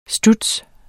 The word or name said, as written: studs